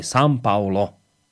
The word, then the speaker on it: São Paulo: Uh, e, uh, toto, aho, chlapi, prosím, aspoň vy nevyslujte názov mesta São Paulo ako São Paulo. Správna výslovnosť je São Paulo. Je tam nosovka.